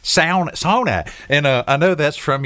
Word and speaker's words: sauna: The correct pronunciation is sauna.